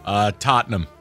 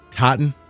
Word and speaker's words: Tottenham: I believe it's called Trottenham Hotspur. – Tottenham. – Tottenham. – Trottenham. I mean, this is a mispronunciation buffet. Tottenham, the EPL team.